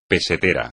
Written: pesetero

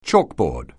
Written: chalkboard

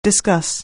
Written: discuss

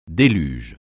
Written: déluge

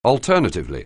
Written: alternatively

